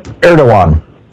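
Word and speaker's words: Erdoğan: Erdogan is how it's pronounced.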